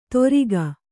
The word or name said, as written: ತೊರಿಗ